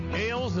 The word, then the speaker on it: Ailes: Nick Ailes. Am I saying that right? – Sounds good. – A-I-L-E-S